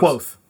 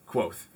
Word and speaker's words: Kvothe: Uh, they tell the story of uh, Kvothe, just how I believe it's pronounced. Quoth. – Quoth.